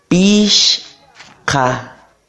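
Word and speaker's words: pichqa: pish pish ka como un sonidito más hacia atrás en la última parte. Ka. Ka. Decimos 5 es pish ka.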